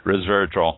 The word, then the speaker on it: resveratrol: and I keep saying that wrong, putting the V before the S, resveratrol, and all that.